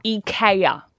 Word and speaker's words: IKEA: IKEA. Is the way we're meant to pronounce it, as a Swedish.